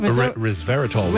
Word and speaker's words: resveratrol: This person has been studying the potential benefit of resveratrol. Did I say that right? – Resveratrol. – Resveratrol.